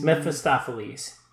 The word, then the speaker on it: Mephistopheles: but he is outdone by one man in this movie, uh, M, which I think stands for Mustapheles. What is it? Mephistopheles? – Mm. Mephistopheles.